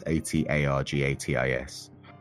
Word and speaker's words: Atargatis: This goddess Atargatis is how I'm going to say it. It's pronounced Atargatis.